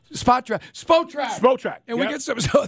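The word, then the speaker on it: Spotrac: It is Spot Rack. Tell us, is that correct? – It's Spot Track. So it's like, take the word spot and the word track and mash them all together.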